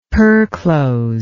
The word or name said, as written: perclose